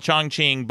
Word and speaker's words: Chongqing: It's a city called Chongqing, but it's not C-H-I-N-G. It's C-H-O-N-G-Q-I-N-G. And the Q in Chinese is pronounced ch.